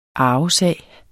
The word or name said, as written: arvesag